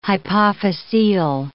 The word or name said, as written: hypophyseal